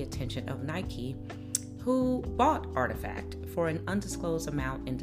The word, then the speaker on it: RTFKT: RTFKT, pronounced artifact, is one of many labels blending the two landscapes.